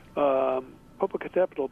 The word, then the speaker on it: Popocatepetl: Popocatepetl, the great volcano near Mexico City, is suddenly erupting and throwing smoke 4 miles up into the sky. Popocatepetl, by the way, so few Americans know how to pronounce that.